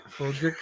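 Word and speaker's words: Frederick: Did they also pronounce his name as Froderick? – Froderick.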